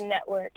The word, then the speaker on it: NTWRK: And the network is spelled NTWRK.